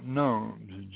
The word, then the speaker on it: gnomes: We are the gnomes, all gathered around you in your home, and speaking through the telepathic phone that is spelt gnomes, G-O-G-N-O-M-E-S, a silent G.